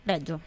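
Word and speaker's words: Regio: Ma c'è anche il teatro, ma non Lescaut, teatro Reggio o Reggio? – Reggio. – Reggio, grazie.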